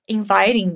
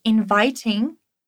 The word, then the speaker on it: inviting: Ou uma pronúncia mais britânica, inviting. Inviting. As duas formas são aceitáveis.